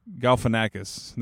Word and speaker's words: Galifianakis: Zach Galifianakis did I say that right? Galfinakis. That's how you say it.